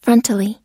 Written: frontally

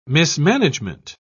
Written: mismanagement

